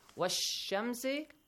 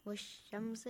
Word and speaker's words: والشمس: Wash-shamsi. – Wash-shamsi. – Do I pronounce the lam? Do I say the lam? I skip it. So I directly join waw to sheen.